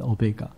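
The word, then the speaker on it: Omega (ὠμέγα): 真正的读音是这样读的，阿尔法跟 Omega，可是我们中文把它翻译成阿拉法跟俄美甲。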